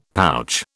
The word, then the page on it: pouch: British English